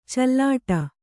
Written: ಚಲ್ಲಾಟ

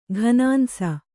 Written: ಘನಾಂಸ

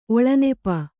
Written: ಒಳನೆಪ

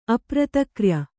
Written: ಅಪ್ರತಕ್ರ್ಯ

